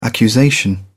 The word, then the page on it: accusation: British English